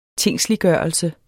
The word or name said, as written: tingsliggørelse